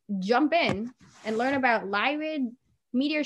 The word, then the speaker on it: Lyrid: Basically, I think the most recent, or the upcoming one is the meteor shower, the Lyrid meteor shower, if I'm not mistakenly pronouncing it. – No, you're doing perfect. That's exactly how you say it.